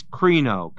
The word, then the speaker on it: krino: And that word judge is krino, K-R-I-N long O, which is to pronounce a sentence or a judgment.